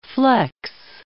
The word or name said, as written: flex